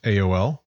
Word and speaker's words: Aeol: So the dock master is named Aeol, A-E-O-L, pronounced A-O-L, who is the dock master. Your keen-eared barkeep is named A-Track, A-Y-T-R-A-K.